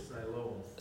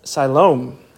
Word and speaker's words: Siloam: Siloam. – Siloam.